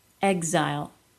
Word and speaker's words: exile: These words are pronounced as G plus Z, even though the stress in the word would not dictate it. Exit. Existential. Exile.